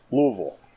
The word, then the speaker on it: Louisville: No, it's actually almost two syllables. Louisville.